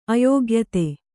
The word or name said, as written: ಅಯೋಗ್ಯತೆ